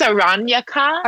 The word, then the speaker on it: Aranyaka: And it's called Aranyaka. Did I say it right? I always Spanishize. – I think it's Aranyaka. – Aranyaka. I always Spanishize all Sanskrit. So it's Aranyaka.